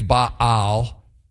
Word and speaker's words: Baal: Actually, technically, it's Baal is how you say it, but I'm not even going to try that because I'll just be hacking all over everybody. I'll just say Baal.